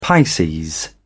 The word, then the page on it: pisces: British English